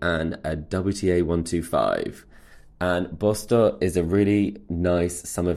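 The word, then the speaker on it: Båstad: in Bostor, which you might know as Bastad, but that's how you say it, I learned whilst I was there.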